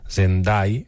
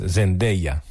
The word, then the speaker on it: Zendaya: Vaig veure un vídeo un dia que ella ho explicava, perquè es veu que la resta del món li diuen Zendai, però Dai és com morir, no? Dai. I s'escriu Dei com dia, i és Zendaya.